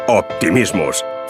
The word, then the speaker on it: Optimismus: ¿Sabes cómo se dice optimismo en alemán? Optimismus.